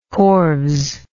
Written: corves

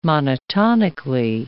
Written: monotonically

